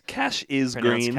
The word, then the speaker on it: Cassius Green: Cash is Green. – Cash is Green. – They sort of pronounce it like that, like an hour into the movie.